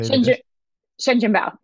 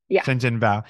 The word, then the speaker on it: Shenzhen bao: correct my pronunciation if it's wrong, but is it Shenzhen bao? Is that how you say Shenzhen bao? Yeah. – Shenzhen bao.